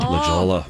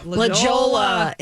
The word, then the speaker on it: La Jolla: Rachel says, a few years ago, she was talking to some customers about travel, and I proceeded to pronounce La Jolla phonetically with the J sound. – Oh, La Jolla. – La Jolla.